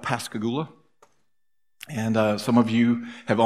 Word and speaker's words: Pascagoula: Pascagoula. And he was asked one time why he used that, and he said, it rhymes with hallelujah.